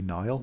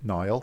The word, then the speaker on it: Niall: Well, it's my name spelled the Irish way, so it should be Niall. But then in 1970s Shropshire, no one knew how to say it, Niall.